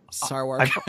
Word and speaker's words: Sarwark: Is it Sawark or Sarwark? How do you say his name? – It's spelled Sarwark. I believe it's pronounced Sarwark. – I've heard people pronounce it. – Not going to work here anymore. – I've introduced him on the show twice, so I think I said his name right both times.